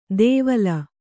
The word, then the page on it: ದೇವಲ: dēvala